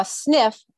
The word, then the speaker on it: SNF: A SNF is spelled SNF, and that stands for skilled nursing facility. – Okay. Nursing facility, a SNF.